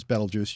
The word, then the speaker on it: Betelgeuse: The preferred pronunciation is Betelgeuse, just as you said it.